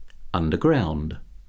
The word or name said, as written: underground